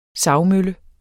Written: savmølle

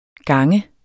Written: ganger

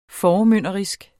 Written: formynderisk

